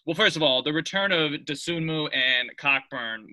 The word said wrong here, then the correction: Cockburn: was Coburn. – Massive. – Coburn. It's spelled Cockburn.